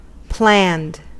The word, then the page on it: planned: /ˈplænd/